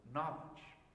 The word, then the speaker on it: knowledge: spiritual knowledge. That's what Gnosticism means in the Greek. Have you ever wondered why the K is silent in knowledge?